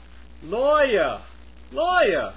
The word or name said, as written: lawyer